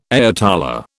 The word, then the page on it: ayatollah: British English